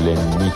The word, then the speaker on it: Ляни: Квартира принадлежала его другу, манекенщику Ляни. С ударением на «и», уточнил он.